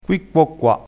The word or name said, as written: qui quo qua